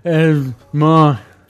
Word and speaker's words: Edvard: And like, so like last week, Edward Munch, it's like the U is a V. So I'm never sure how to pronounce that guy's name. I think, or maybe it's Edvard Munch.